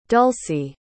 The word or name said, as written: Dulcie